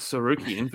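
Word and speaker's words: Tsarukyan: Armin T is silent Sarukian versus Mateus.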